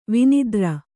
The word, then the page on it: ವಿನಿದ್ರ: kannada